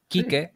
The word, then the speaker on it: Kike: Porque Kike, sí, con K, como lo escribo, es un insulto.